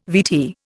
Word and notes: VT: Indian

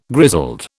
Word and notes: grizzled: British English

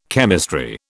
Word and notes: chemistry: British English

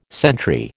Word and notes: sentry: American English

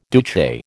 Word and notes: Duce: American English

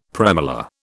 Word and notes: premolar: British English

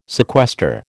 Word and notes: sequester: American English

